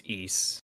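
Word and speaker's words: Ys: It's pronounced East.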